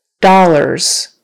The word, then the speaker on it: dollars: Dollars, dollars. So not dollars or dollars or whatever. It's two syllables, dollars. Accent is on the first syllable.